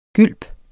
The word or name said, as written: gylp